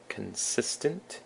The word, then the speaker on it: consistent: So consistent has the emphasis on the sis, consistent.